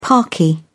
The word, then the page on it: parky: British English